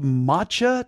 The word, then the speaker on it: matcha: is it called matcha tea? Is that how it's pronounced? – Yeah, just that matcha itself is disgusting.